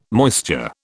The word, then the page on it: moisture: British English